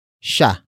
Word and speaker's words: siya: After that, we have sha, which is spelled as S-I-Y-A.